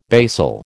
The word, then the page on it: basal: American English